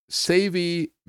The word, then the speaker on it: Savey: The Savey Meal Maker, they spell it S-A-V-E-Y. I mean, you might think it's savvy, but actually this thing wasn't too savvy. It's a Savey Meal Maker.